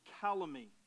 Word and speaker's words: calumny: There's a word called calumny. Let's say that together. Ready? Calumny. It spells like this. C-A-L-U-M-N-Y. The N is silent.